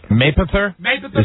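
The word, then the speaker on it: Mapother: So his official name is Thomas Cruise Maypower the Fourth. – Maypower or Mayfower? – Maypother. – Is that the correct pronunciation? – Wow.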